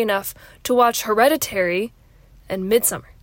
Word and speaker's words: Midsommar: When I had a film history class, they assigned Midsommar, that's the correct way to say it, and Hereditary as homework viewing assignments, and I care about getting a good grade.